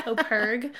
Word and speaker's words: OPERG: Yeah, so usually I describe it as like purr like a cat, the in-between, OPERG.